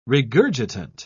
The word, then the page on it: regurgitant: British English